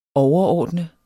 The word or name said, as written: overordnet